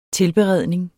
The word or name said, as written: tilberedning